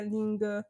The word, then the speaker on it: LingQ: Się wymawia Ling, Ling i Q, na kamień później, się pisze.